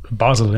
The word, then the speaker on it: Basel: Basel, yeah.